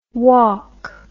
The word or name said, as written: wauk